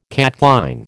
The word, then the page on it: catchline: American English